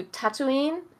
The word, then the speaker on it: Tatooine: They go to Tatooine.